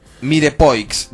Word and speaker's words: mirepoix: Mirepoix, dice Miguel, que se dice Mirepoix y se escribe Mirepoix.